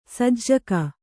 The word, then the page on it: ಸಜ್ಜಕ: kannada